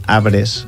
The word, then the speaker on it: arbres: Crec que és recomanació de no pronunciar-la. Pedre, arbres, és la pronunciació més... – Pedre, correcte, en tot cas més natural.